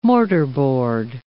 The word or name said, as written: mortarboard